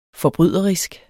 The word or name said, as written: forbryderisk